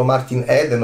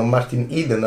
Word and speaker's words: Martin Eden: veniamo da un momento molto forte di Jack London negli ultimi anni con Zanna Bianca in animazione con il nostro Martin Eden o Martin Eden a seconda di come si voglia pronunciare e c'è una citazione di Jack London, eh, devo dire, molto bella verso la fine del film.